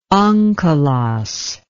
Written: onkelos

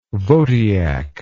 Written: Votyak

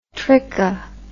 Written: tricha

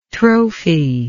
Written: trophi

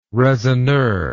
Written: raisonneur

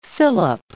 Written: fillip

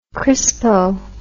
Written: crispo

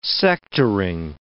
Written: sectoring

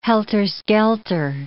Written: helter-skelter

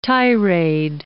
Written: tirade

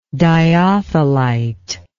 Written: dyothelite

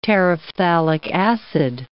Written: terephthalic acid

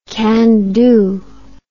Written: candu

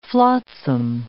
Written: flotsam

